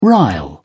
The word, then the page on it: rile: British English